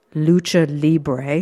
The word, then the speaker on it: Lucha Libre: And I'd like you to tell us a little bit about Lucha Libre.